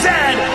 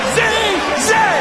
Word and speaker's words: Z: The toque is a hat, a Chesterfield is a coach, and it is pronounced Zed, not Z-Z.